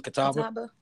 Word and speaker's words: Catawba: That's how you say it again? Catawba? – Yes.